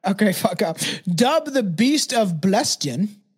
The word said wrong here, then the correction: Belsen: Belsen. – Belsen.